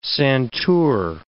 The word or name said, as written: santour